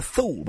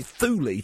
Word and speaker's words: Thule: I always called it Ultima Thule, but apparently Thule to rhyme with Julie, which takes some of the kind of drama away from it.